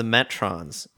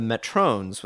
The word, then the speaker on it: Metrons: They call themselves the Metrons, note the short O. Kirk calls them the Metrones with a long O. One of your hosts called them the Metrions.